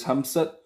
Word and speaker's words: Hamzat: es Hamsat. Es, el día que no puedo, no puedo pronunciar el nombre bien, Ham, Hamzat Es que siempre le pongo la al final del Hamzat, pero Hamzat Chimaev creo que de los tres es él,